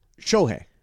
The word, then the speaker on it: Shohei: Is it Shohei or Shohei? – Shohei. – Shohei. – Yeah, it's not Shohei. – S-H-O-H-E-I.